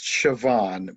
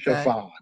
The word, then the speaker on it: Siobhan: I think it's pronounced Siobhan, because I know another person with that same name, and I was pronouncing it wrong for the longest time until I heard it say it out loud. I think it is Welsh? I don't remember, but it's pronounced something like Siobhan.